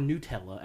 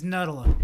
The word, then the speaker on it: Nutella: Nutella, or Nutella, as it's actually supposed to be pronounced, some people. – It's Nutella.